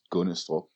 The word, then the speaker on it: Gundestrup: It's spelled G-U-N-D-E-S-T-R-U-P. And of course, it's pronounced Gunnestrup because Danes hate consonants.